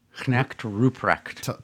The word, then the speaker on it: Knecht Ruprecht: How do you say that? Connect? – Knecht Ruprecht.